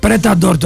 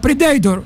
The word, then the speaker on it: Predator: Πρέταντορ το έλεγε η Σοφία, η βούλτευση στον Αντώνη το πρωί. Τι Predator ρε Σοφία, Predator. Πες το με προφορά ρε Σοφάκι.